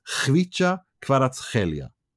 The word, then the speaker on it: Kvicha Kvaratskhelia: Yeah, but he pronounces it Kvicha Kvaratskhelia. That's him. That's according to him, not me.